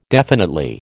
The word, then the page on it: definitely: American English